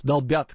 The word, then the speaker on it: долбят: Долбят, кстати, правильное ударение.